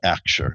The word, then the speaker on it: Axure: It's called Axure. It's spelled A-X-U-R-E.